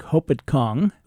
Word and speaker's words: Hopatcong: I'd never heard of Lake Hopatcong, whose outflow is the Musconetcong River. And I know those pronunciations are correct because I called the Musconetcong Watershed Association to make sure. That's right, Musconetcong.